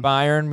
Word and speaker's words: Bayern: Bayern Munich, okay.